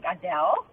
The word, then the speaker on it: Adele: Well, my name is spelled A-D-E-L-E, like Adele. – Yeah. – But because I think it's an accent thing. Personally, I think it's an accent thing because we're from South Africa, so we pronounce it in Afrikaans, so it's Adele.